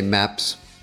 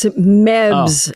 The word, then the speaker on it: Mebs: Lorraine, I don't want to keep you forever, but do you ever get mad and say Meps? – Say what? – Meps. Meps. Meps. What are you saying, Coneheads? Meps. – It's Mebs. Oh. M-E-B-S. Mebs. Wow. M-E-B.